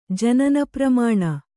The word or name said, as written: ಜನನಪ್ರಮಾಣ